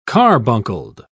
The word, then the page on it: carbuncled: British English